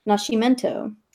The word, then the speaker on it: Nascimento: Therefore, her surname is Portuguese and should be pronounced Nascimento.